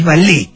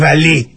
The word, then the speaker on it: valet: Basta de decir valet parking, no, es valet. Basta, no hay T, no se pronuncia, quédate tranquilo, man. No sos un grasa que dice vamos, ah. Es francés, es valet, es pichó. Sin ningún tipo de té, de nada.